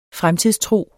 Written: fremtidstro

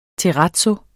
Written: terrazzo

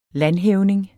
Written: landhævning

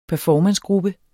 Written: performancegruppe

